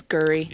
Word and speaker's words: Gery: Although Paula develops feelings for Jerry, it's spelled G-E-R-Y, so I'm guessing Jerry. – Jerry. – It's Gurry. – Yeah, Gurry.